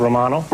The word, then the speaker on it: Romano: Romano, pronounce Romano.